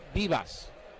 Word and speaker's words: Vivas: J-O-R-B-I-T-V-I-V-A-S, but he pronounces it Vivas, like they're bees.